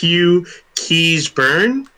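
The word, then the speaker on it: Keays-Byrne: Next up we have, I believe this is pronounced Hugh Keysburn, even though it's spelled strangely for an American.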